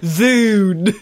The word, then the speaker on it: Dune: And so, we are talking Dune, as they pronounce Dune? – It. Dune. It's Dune? June. – excuse me, I said June. – June. – With a J.